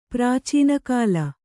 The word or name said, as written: ಪ್ರಾಚೀನಕಾಲ